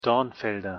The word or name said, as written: Dornfelder